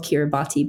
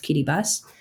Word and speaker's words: Kiribati: So in 2014, a 36-year-old farm worker from the Pacific island of Kiribati, spelled Kiribati but pronounced Kiribati, a man named Ayoni Tesiota, was caught in New Zealand with a broken taillight on his car.